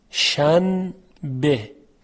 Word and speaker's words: شنبه: ببینید در فارسی ما این مثال رو داریم، شن به. خب ما میگوییم شنبه، یعنی نون ساکن که قبل از به قرار گرفته در تلفظ تبدیل میشه به میم، شنبه.